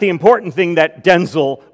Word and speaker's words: Denzel: Denzel, and they both would answer, "Yes," that his mama, in frustration, told him, "Son, your name is now pronounced Denzel, because I'm sick of all the confusion."